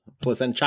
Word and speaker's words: Placentia: Yeah, Placenta. – Placenta, yeah, California. – And Placentia. – Yeah. Placentia. Yeah.